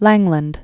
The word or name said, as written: Langland